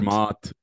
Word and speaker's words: Zermatt: So I went to Zermatt. Is that how you pronounce it? Zermatt. – Zermatt.